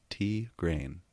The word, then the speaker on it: Tigraine: Pronounced Tigraine. – I'm still going to say Tigraine. – That just bothers me. I don't know why. Gotcha. Emphasis on the grain. Tigraine.